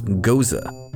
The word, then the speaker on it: Gose: Our beer today is Wheels Goes Around by Left Hand Brewing, and yes, that's pronounced Goza.